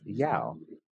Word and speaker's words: YAW: Spelled YAW, but pronounced Yao.